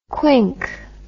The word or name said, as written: Quink